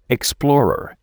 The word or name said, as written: explorer